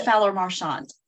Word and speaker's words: Fowler-Marchant: It's Fowler Marchant.